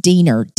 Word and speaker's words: Diener: I believe it's pronounced Diener, D-I-E-N-E-R.